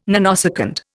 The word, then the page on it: nanosecond: Indian